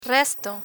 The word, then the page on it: resto: spanish